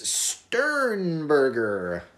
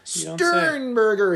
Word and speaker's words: Sternberger: Jace Sternberger. That's how they say his name in Green Bay. – Oh yeah? – Sternberger.